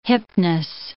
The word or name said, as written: hipness